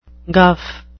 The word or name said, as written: guff